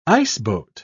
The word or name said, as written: iceboat